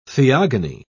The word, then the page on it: theogony: British English